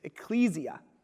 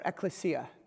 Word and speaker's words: ekklesia: The word for church in the scriptures, when it began to be used in the New Testament, is ecclesia, or ekklesia. People pronounce it differently.